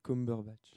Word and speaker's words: Cumberbatch: C'est Cumberbatch. – Et du coup, comment ça s'écrit? – Ça s'écrit. Arrête, Mathias. – Bah, je m'intéresse. – Ça s'écrit bon. – C-U-M-B-E-R-B-A-T-A-T-C-H. Pardon. Ça fait Cumberbatch. – Tu peux répéter. – C-U-M.